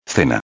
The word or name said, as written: cena